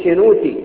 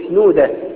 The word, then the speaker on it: شنودة: أما اللحن الأدريبي فهو نسبة إلى أتريب، مش اللي هي بنها، في أتريب جنب أخميم جنب دير الأنبشنورة الدير الأبيض، وهناك بالنطق الصعيدي التاء ينطقوها دال، ما يقولوش شنوتي يقولوا شنودة، ما يقولوش أتريب يقولوا أدريب.